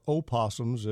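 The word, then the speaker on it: opossum: The possums or opossums, as you properly pronounced it, are really usually not much of a problem.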